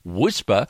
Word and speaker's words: WSPR: WSPR, pronounced Whisper, stands for Weak Signal Propagation Reporter.